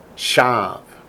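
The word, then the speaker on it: Shav (שָׁוְא): Shav. – Not shuv, which is return, repent, but shav. It's vain, it's empty, it's worthless.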